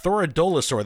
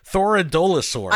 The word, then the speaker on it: Thoradolosaur: It's Thoradolosaur. Thoradolosaur. There's an extra syllable in there. We're both pronouncing it wrong. Thoradolosaur.